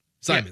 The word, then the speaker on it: Simons: Now I see his name here is spelled Simons. Simmons is with two M's. His name is spelled Simons, S-I-M-O-N-S. I would have called him Simons. – Would you have? – Yeah, it's like Paul Simon.